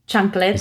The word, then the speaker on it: chancletas: Number one, can you tell me what chancletos are? Am I saying that right? – Chancletas? – Is that a thing? Yes. Yes. That was much better.